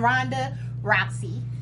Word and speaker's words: Ronda Rousey: So, of course, everybody knows the UFC champion, Ronda Rousey.